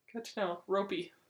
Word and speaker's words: Roope: Anonymous said to Misunderestimated, just want every Dallas fan to know that our new favorite boy, Roope Hintz, name is pronounced like rope with a long O and an E-Y at the end. E-Y. So it's ropey.